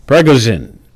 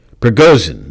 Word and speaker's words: Prigozhin: Prigozhin may be gone, but not the failings he ranted about. Prigozhin, that's what it is. I mispronounced it.